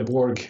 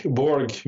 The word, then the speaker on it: Borge: Just say Borge. Borge. Most people just say Borge.